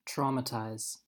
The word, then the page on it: traumatize: American English